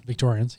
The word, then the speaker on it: Victorians: I just wondered if they'd let those dirty Victorianers across into West Australia, knowing what they're like. – You say Victorian Annas? Victorians? – Victorians, yeah. – There's definitely a syllable on there that didn't need to be.